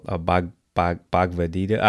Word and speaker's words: Bhagavad Gita: And the book was, if I mispronounce the name, sorry, but it's Bhagavad Gita. – Oh, Bhagavad Gita.